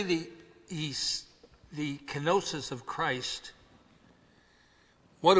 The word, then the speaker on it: kenosis: And it's shown in several things, and the first thing it's shown in is his kenosis. That's a Greek word. K-E-N-O-S-I-S. We spell it. But the Greek word is kenosis.